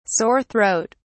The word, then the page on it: sore throat: British English